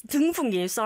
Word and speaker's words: 등풍기: You just said 동풍기. – Sorry. – Now, since you corrected my pronunciation, I'm going to correct you too. You said 동풍기. What is a 동풍기? – It's 등풍기. Sorry, guys.